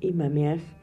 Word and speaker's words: Imamiah: Tu ángel se llama Imamiah. y se escribe así, I latina, Mami, A-H, I latina, M-A-M-I-A-H.